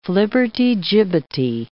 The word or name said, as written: flibbertigibbety